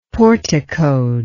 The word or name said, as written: porticoed